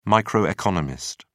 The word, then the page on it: microeconomist: British English